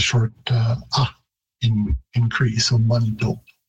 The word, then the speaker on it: Manitou: And again, also Manitou, all three syllables are being placed in the English pronunciation, whereas in Cree, it would be very common to sort of reduce that middle syllable right out of there. And Manitou, it sounds more like a two-syllable word.